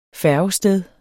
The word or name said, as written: færgested